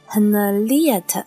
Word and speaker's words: Henriette: 事实上，也正是主唱亨利娜，呃，她的名字好像读作 Henriette，她辨识度极高的女声。